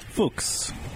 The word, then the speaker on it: Fuchs: It says rhymes with books. – Fuchs. – That was it. – Books with an F.